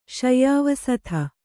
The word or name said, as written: ಶಯ್ಯಾವಸಥ